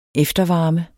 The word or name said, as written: eftervarme